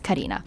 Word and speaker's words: Carina: But I guess growing up, I was always I always knew that there were 2 different ways to pronounce my name, and some people couldn't pronounce it Carina, and that's just something that I grew to be used to.